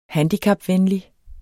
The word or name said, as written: handicapvenlig